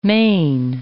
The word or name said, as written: mane